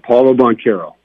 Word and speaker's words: Paolo Banchero: Help me out with the pronunciation, because I know a lot of Duke fans are going to be saying this guy's name. Is it Paolo Banchero? – Yeah, Paolo Banchero. – Paolo Banchero. Okay, you heard it here first from Coach.